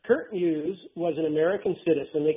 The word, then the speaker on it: Kurt Muse: This dealt with a fellow by the name, if I'm pronouncing his name right, Kurt Muse? – Correct.